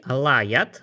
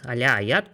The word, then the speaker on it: Алля-Аят: Ну хорошо, то есть э, руководителем и богом в прямом смысле вот этой секты, Алла Аят, Аля Аят по-разному произносится,